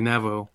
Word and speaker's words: Navajo: Navajo, is that right? Navajo? – It's actually Navajo.